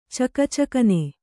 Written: ಚಕಚಕನೆ

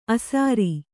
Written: ಆಸರ

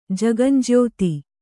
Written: ಜಗಂಜ್ಯೋತಿ

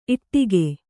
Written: ಈಡಿಗ

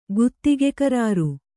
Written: ಗುತ್ತಿಗೆಕರಾರು

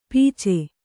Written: ಪೀಚೆ

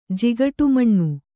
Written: ಜಿಗಟುಮಣ್ಣು

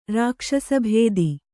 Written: ರಾಕ್ಷಸಭೇದಿ

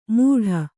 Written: ಮೂಢ

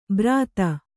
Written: ಬ್ರಾತ

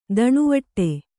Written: ದಣಿವಟ್ಟೆ

